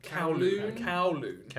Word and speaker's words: Kowloon: And on the right, Kowloon. Am I saying that right? – Kowloon.